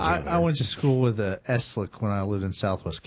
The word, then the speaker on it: Eslick: Also new to the teaching area over at Winoka, Shannon Eastlick. Am I saying that correctly? Eastlick, E-S-L-I-C-K? – It's Eslick.